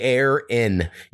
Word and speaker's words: Aaron: And Aaron was like, Aaron. And I was like, well, that's not how I pronounce my name. So I went in there and made it say air. Because I say air in.